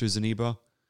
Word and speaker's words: Zeniba: then she goes off to return the trinket to Zeniba. I feel like I'm saying that wrong every time, but I mustn't be.